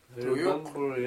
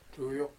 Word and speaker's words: Duyuk: How do you say chief? – Duyuk. Yeah, that one. – Duyuk. – Yeah, Duyuk.